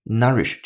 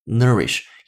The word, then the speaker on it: nourish: 这个句子是出自于《舌尖上的中国》第一季，倾向于英音的发音方式，所以这个单词你听的声音更倾向于 nourish 这样的声音，而美音呢是 nourish，也就是中间这个 o u 字母组合发音处理的不一样，英音是啊，美音呢是倾向于 a 的处理。